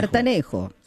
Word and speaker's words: sertanejo: Se escribe sertanejo. – Sertanejo. – Se pronuncia sertanejo.